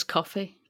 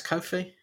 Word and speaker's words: Ko-fi: We decided it was coffee. – No, we heard the woman on the video. – Yeah, but you said it was coffee. – No, it's Ko-fi.